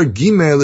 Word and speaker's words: gimel: So, for example, makes this comment, oh, the letter gimel is pronounced as a g, not like the Arabic j, which some people pronounce today, like in the word jabar.